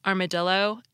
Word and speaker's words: armadillo: Let's look at these three words tortilla, armadillo, and guillotine. We pronounce the two L's in tortilla like a Y, as they are in Spanish. Yet in armadillo, we pronounce them like an L, now let's consider guillotine from French.